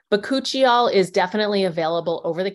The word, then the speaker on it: bakuchiol: It's spelled B-A-K-U-C-H-I-O-L, bakuchiol. I